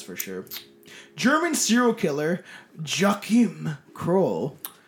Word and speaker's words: Joachim: No, it is. It's literally pronounced Joachim.